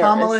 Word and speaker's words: Kamala: Kamala Harris. For all you people who want to know, that is her name. Kamala. Not Kamala. Not Kamala, not Camilla.